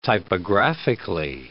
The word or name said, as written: typographically